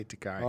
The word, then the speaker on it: Takei: Yeah, and they've been saying George Takei forever, too. – It's Takei. – People don't know. That's right. – No, like, it's always been Takei. – No, people always say Takei.